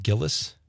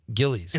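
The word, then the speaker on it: Gilles: How do you say that? Is it Gillis? – Gillies.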